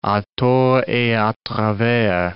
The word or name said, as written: a tort et a travers